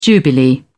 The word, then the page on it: jubilee: British English